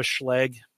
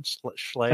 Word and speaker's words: Schlage: We have a Schlage is that the right pronunciation? Schlage.